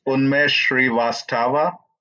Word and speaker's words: Unmesh Srivastava: It's Unmesh Srivastava.